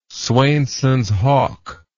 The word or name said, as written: swainsons hawk